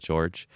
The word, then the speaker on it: Jorge: And his name is Chef George, or Jorge. It's spelled like Jorge, but he says it George.